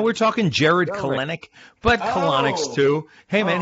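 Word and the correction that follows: Kelenic: It's actually Kelnick. You don't say the other E.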